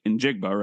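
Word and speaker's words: Smith-Njigba: which is the dude from Ohio State in Jigba, right? That's how you say it. – Jackson Smith and Jigba. Yep.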